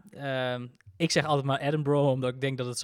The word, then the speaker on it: Edinburgh: Want de start is een heel stuk noordelijker, of eigenlijk oostelijker, in, zoals jij het zegt, Edinburgh. – Ja, volgens mij is dat hoe je, hoe je het moet uitspreken. Eh, dat wat altijd een beetje gek is, omdat er eigenlijk e eh, Edinburgh staat, met een H erachter.